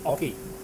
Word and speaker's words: Ochre: Now, their website says it's like hockey, but without the H. So it's Ochre.